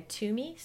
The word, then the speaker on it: Twomey: On YouTube, you can search by my name, Rebecca Twomey, spelled like the number two, T-W-O-M-E-Y, or by searching The Radiant Mission.